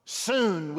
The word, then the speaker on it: sun: S-U-N on the front. Soon we would pronounce it in the Greek New Testament. Not S-O-O-N, but S-U-N. Soon.